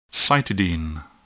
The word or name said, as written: cytidine